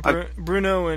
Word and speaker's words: Brno: Brunn in German, which is like B-R-U umlaut N-N, and I think in Czech it's Brno, which is, if I'm pronouncing it correctly, which is B-R-N-O. Yeah. – That's right.